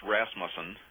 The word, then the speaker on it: Rasmussen: R-U-S-S? – No, my last name is spelled it's pronounced Rasmussen. And Rasmussen. – It's R-A-S-M-U-S-S-E-N. – All right, R-A-S. – M-U-S-S-E-N.